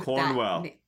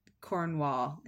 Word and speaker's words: Cornwell: It's not that it's a hard name; it's that there's a more common version of Cornwell. – Cornwell, yeah, I always want to say Cornwall instead of Cornwell.